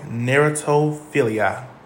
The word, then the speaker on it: Narratophilia: Narratophilia, okay? So just think about a narrow, and then a toe, like your toe and your foot, then Philly, like the city, Philadelphia, and then yeah, like yeah.